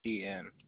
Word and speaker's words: Stephen: Stephen.